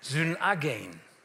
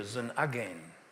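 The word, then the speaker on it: synagein (συνάγειν): Das griechische Wort, das hier verwendet wird von Jesus, für versammeln, synagein. Betonung liegt auf der zweiten Silbe, synagein.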